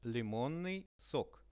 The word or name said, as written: limonnyi sok